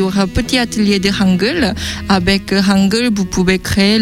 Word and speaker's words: Hangul: Hangul, voilà. Hangul, bon, H, on le prononce bien en français, en coréen, pardon, en fait.